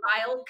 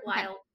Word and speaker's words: Lisle: Yes, at Heather Lyle Co. And my last name is spelled L-I-S-L-E. A lot of people don't spell it right. But anyway, Heather Lyle Co.